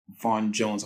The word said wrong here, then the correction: Van Jones: I hope I'm not mispronouncing his name. Who mentioned something, and I think... – Oh, Van Jones. – Van Jones, sorry.